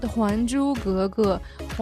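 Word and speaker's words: Huan Zhu Ge Ge (還珠格格): It's called Huan Zhu Ge Ge. Huan means return by the character, Zhu means pearl, but you don't say return the pearl.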